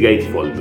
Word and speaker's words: gatefold: Antes te hago por las dudas, digo, antes de que vayas al otro capítulo anterior, te hago un pasaje por el Gateful de adentro. Mira, lo que es esto. Gateful, dije, ¿no? O sea, quise decir Gatefold, pero dije, pronuncié Gateful. Sería el, portal del tonto.